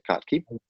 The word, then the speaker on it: Kottke: I think that's how you say it, Kottke. – Yeah.